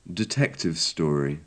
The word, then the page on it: detective story: English